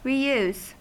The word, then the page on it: reuse: American English